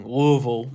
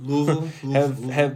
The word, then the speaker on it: Louisville: I know I'm saying it wrong. Louisville. – Louisville.